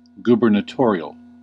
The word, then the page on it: gubernatorial: /ˌɡuː.bən.eˈtɔː.ɹi.əl/; /ˌɡu.bɚ.neˈtɔɹ.i.əl/